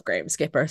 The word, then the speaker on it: Graham Skipper: Is that his name? I think I'm saying his name wrong. I'm going to cut this out anyway. Yeah, it is Graham Skipper. Thank you.